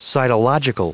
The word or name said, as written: cytological